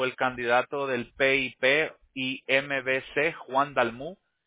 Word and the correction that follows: Dalmau: Dalmau, no lo pronuncio bien. – ¿Dalmau? ¿Juan Dalmau? – Sí, Juan Dalmau.